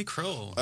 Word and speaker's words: Kroell: Ronnie Kroell.